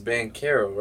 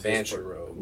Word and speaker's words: Banchero: Because it's Bancaro, right? Not Banchero.